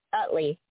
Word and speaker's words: Utley: Utley. U as in uncle. T as in Thomas. L-E-Y.